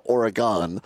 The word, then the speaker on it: Oregon: The problem is that the suburb of Madison, they pronounce it Oregon, and not the state is Oregon.